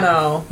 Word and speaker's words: hermano: Hermano. – Yep. – You don't pronounce the H.